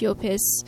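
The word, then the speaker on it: Llopis: It's L-L-O-P-I-S, which I think is pronounced Yopis.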